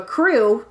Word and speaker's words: Krewe: You may need the help of a crew to plan this properly. Crew is spelled K-R-E-W-E if it helps.